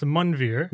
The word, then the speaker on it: Manveer: So, the A in an Indian language in Punjabi, which I'm Punjabi Sikh, is more like a U. – Okay. – So it's actually pronounced Manveer.